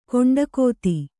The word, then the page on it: ಕಂಠೋಕ್ತ: kaṇṭhōkta